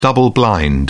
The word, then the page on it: double blind: British English